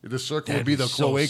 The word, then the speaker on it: cloaca: It's pronounced cloaca.